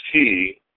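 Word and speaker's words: he (היא): The parasha has no words that are written one way and spoken another, although there are two times that the word she, which is pronounced he, appears.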